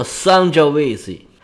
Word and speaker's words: Sangiovese: 说到意大利红酒，咱们可以再记一个词儿，叫做桑娇维塞，这是中文，意大利语读作 Sangiovese，这是意大利最传统的葡萄品种。